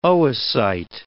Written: oocyte

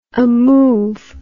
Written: amove

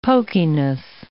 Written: pokiness

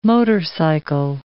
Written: motorcycle

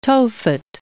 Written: Tophet

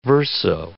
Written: verso

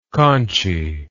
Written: conchy

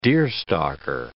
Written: deerstalker

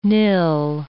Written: nil

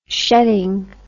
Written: shedding

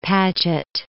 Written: Paget

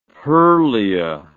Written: Perlea

